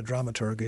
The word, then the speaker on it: dramaturg: What a dramaturg is, and all. Is that, have I even pronounced that correctly? – Yeah, that's good. Okay. Hard G.